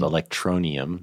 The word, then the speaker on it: Electronium: It was called Electronium.